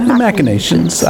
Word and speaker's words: machinations: Machinations. I would do a hard CH on that one.